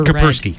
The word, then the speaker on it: Kaspersky: Am I pronouncing it properly now? Kaspersky? I've only ever Kaspersky. – Read. Kaspersky. – Yes. – Sorry, I'm trying to pronounce it like a not Russian person.